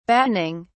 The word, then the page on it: battening: British English